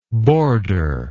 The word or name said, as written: borderless